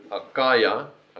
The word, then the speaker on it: Achaia: Apollos had been thinking about going to, okay, and I googled actually how to say this word, Achaia, and the brothers and sisters in Ephesus encouraged him to go.